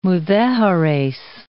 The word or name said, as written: Mudejares